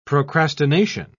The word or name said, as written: procrastination